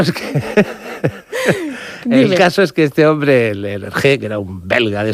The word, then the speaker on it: Hergé: y utilizó la R de Remy, que allí se pronuncia R, R de Hergé, y el nombre de George G. RG.